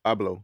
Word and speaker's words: Abloh: That Virgil, how you pronounce his last name? – Abloh. Yeah.